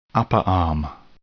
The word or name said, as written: upper arm